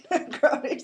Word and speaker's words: Negroni: Negroni. Yes, Negroni, like the drink. The millennials know what it is because it's a craft cocktail, Negroni. – Okay, Negroni.